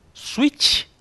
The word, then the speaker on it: Nintendo Switch: É assim a pronúncia correta, Maguila? Você que é o nosso poliglota, troglodita? Switch? – Está correto!